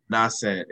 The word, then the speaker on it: Nas: Nasen. It's spelled N-A-S. And then Cent, C-E-N-T. – N-T, yeah.